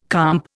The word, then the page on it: comp: Indian